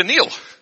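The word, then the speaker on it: kneel: So now we've changed Neil to Neil. But if we're going to spell it phonetically, we're going to say K-N-E-E-L. K. Neil. So we don't actually say the K, do we?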